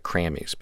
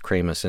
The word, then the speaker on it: Kramies: But and Jason Lytle also produced a new EP from Kramus, and it's spelled Kramus, is K-R-A-M-I-E-S. It looks like Krammies, but it's pronounced Kramus, and it's the EP is called Of All the Places Been and Everything the End.